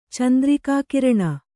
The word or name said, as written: ಚಂದ್ರಿಕಾಕಿರಣ